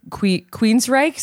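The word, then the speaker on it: Queensrÿche: Trying to sing to all the high notes of like a Queensryche song. Am I pronouncing that correctly? – That's how I've always pronounced it. – That is how it's pronounced.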